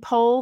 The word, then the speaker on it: Pol: Pol told the story about his name being Paul originally, and his mom, he's Armenian on both sides, so his mom used to say his name Pol. That's how she pronounced it.